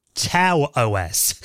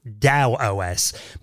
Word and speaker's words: TAOS: I originally thought this was called Tau OS, because I've read this for years. It's spelled T-A-O-S. Turns out Chris teaches me that it's actually pronounced Dow OS.